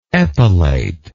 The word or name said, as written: epilator